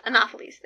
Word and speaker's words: Anopheles: I looked up how to pronounce it. Anopheles. Anopheles? Anopheles. There we go.